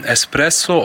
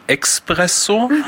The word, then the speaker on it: Espresso: Enorm falsch ausgesprochen wird Espresso, oftmals wird gesagt Expresso.